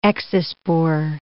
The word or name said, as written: exospore